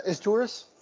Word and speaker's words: Izturis: one, Maicer Isritus. I was thinking about him the other day. – Uh, Izturis? – Yes. Sorry, I said I said his last name wrong.